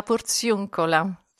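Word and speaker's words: Porziuncola: J'ai encore mal prononcé, de la Portioncula. – Oui, la Portioncula.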